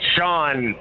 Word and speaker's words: Sean: It's Sean. – Sean, hey, what a great name. How do you spell it? – S-E-A-N.